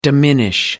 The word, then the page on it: diminish: American English